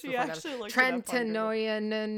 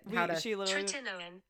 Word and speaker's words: Tretinoin: And then they prescribed me Retin-A, Tretinoin. I spent like 30 minutes trying to figure out how to pronounce this. – Tretinoin. Wait, can you please? – Yeah, I YouTubed it. – Tretinoin. – Tretinoin.